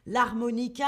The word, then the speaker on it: harmonica: Piano, la guitare, la guitare avec le R, la flûte, hein, le U, très important, la flûte, l'harmonica, l'harmonica avec ce H qu'on ne prononce pas, et attention, la batterie.